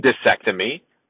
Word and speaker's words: discectomy: Did I pronounce that right? Is it microdiscectomy? – I was going with microdiscectomy, but discectomy. – I could. – Discectomy. There you go.